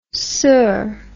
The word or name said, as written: soeur